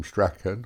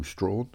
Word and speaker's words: Strachan: That's Malcolm Strachan, or Malcolm Strawn, depending on how you pronounce it or want to pronounce it.